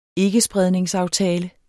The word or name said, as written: ikkespredningsaftale